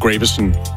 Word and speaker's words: Gravesen: Troede i hvert fald, jeg hed Sebastian Graversen. Mm. Men efter at have hørt udenlandske medier udtale Thomas Gravesens efternavn Graversen, så er det gået op for mig, at jeg måske har sagt mit navn forkert i 10 år.